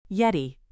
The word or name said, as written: yeti